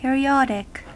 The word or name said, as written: periodic